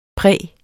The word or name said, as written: præ